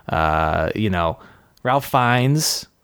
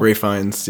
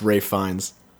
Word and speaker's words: Ralph Fiennes: Wes Anderson was doing his Wes Anderson thing, and Bill Murray was doing his Bill Murray thing, and Ralph Fiennes. Well, Ray Fiennes, yeah. – Ralph. – It's Ray, yeah. It looks like Ralph, but it's actually pronounced Ray Fiennes.